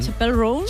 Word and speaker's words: Chappell Roan: Chappell Roan.